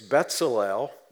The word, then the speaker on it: Bezalel: It's Bezalel, even though it's spelled B-E-Z, it's pronounced Betz, like all bets are off.